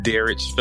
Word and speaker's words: Derrich: So I'm Derrich Phillips. I got a kind of a weird pronunciation for my name. My mom was getting creative. So instead of Derrich, she emphasized the rich.